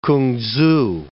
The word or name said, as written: K'ung-tzu